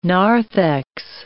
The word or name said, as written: narthex